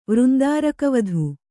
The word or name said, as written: ವೃಂದಾರಕವಧು